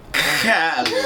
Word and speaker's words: Khaled: Khaled.